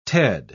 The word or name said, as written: ted